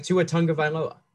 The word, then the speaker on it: Tagovailoa: Like Tua Tagovailoa.